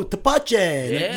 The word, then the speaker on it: tepache: Like, better, yesterday, people kept coming up and being like, I want the pineapple sour. And I'd be like, oh, tepache. Tepache.